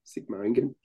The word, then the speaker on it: Sigmaringen: When the Allies approached, landed in France and started approaching Paris, Celine fled with numerous other collaborators to Germany, specifically to Sigmaringen. I don't know if I'm pronouncing that right. – Klaus, Sigmaringen.